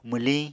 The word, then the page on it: mwliñ: Apatani